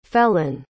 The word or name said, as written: fellen